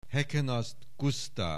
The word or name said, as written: Heckenast Gusztáv